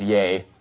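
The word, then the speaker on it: yea: And the chief captain answered, it's actually pronounced yay.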